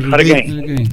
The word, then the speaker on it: Harry Kane: y ya hay una cuestión de que Harry Kane, es así, ¿no? – Harry Kane. – Lo digo bien, sí.